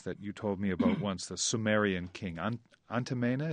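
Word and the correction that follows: Eannatum: is that how you say his name? – Anatum, yes.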